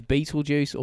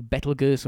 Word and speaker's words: Betelgeuse: And also, so is Beetlejuice or Betelgeuse or however you want to pronounce it. I prefer Beetlejuice because it's a good film.